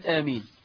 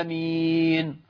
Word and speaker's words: آمين: يمدون مد البدل 6 حركات، وهذا خطأ لغوي بين، إذ أنه لا يمد إلا حركتين. يقولوا إيه بقى الشيخ يقرأ بقى: غير المغضوب عليهم ولا الضالين. تجد الناس يقولون آمين غلط، آمين غلط.